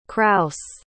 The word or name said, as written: crouse